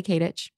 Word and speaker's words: Katich: It's Kylie Kadich. Just my name. – K-A-T-I-C-H.